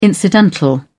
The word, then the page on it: incidental: British English